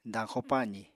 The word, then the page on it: dañxo-pazì: apatani